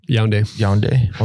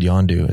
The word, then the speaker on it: Yaoundé: Yaoundé. – I said Yondu.